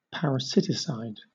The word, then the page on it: parasiticide: British English